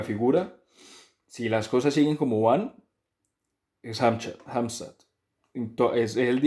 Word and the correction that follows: Hamzat: día que no puedo, no puedo pronunciar el nombre bien, Ham, Hamzat Es que siempre le pongo la al final del Hamzat, pero Hamzat Chimaev creo que de los tres es él,